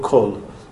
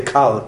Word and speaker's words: kol: So, that when it comes, when the word kaflamet comes in smichut, in connection with another word, so it changes to a kol. But it's a kamatz katan, and it's pronounced as o, not as o, not as a. You don't say kal olam kulo. It's kol olam kulo.